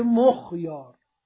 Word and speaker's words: مخیار: بله ببخشید، مخیار رو حالا تلفظ صحیحش رو میگم، میگه به ضم میم و سکون خا مخ و فتح ته تحتیه که یا باشه، میشه مخیار.